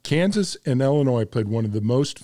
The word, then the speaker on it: Illinois: Illinois. And there's no S at the end. There is an S, but you don't pronounce it.